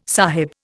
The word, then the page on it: sahib: Indian